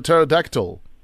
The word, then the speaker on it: pterodactyl: A silent P at the beginning, I think. Is it a pterodactyl? – Yes.